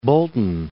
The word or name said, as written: Bolton